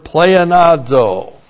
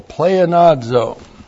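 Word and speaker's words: pleonazo (πλεονάζω): H-I-N-A. That introduces the purpose clause. Hina plus the subjunctive of the verb pleonazo. That's P-L-E-O-N-A-Z, long O, pleonazo.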